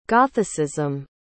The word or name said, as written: gothicism